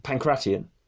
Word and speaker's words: pankration: Pankration? – Pankration.